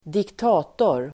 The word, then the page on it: diktator: ²dikt'a:tor